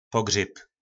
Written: pogryb

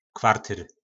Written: kvartyr

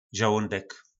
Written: zolondek

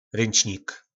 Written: ryncnik

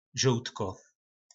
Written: zoltko

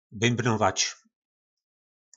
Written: bymbnovac

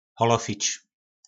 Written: holofic

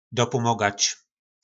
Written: dopomogac

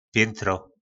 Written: pjyntro

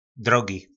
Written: drogi

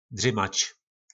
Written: drymac

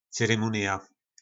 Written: cyrymunija

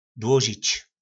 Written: dlozic

